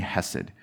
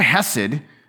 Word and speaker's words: Hesed: That word Hesed, and sometimes you'll see it spelled with an H, sometimes you see it spelled with a ch, the CH, right? Because most Hebrew words have that ch. So if you just start choking, you're probably speaking Hebrew.